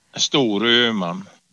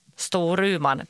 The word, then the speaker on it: Storuman: Hur uttalar man det? – Storuman. – Storuman, precis.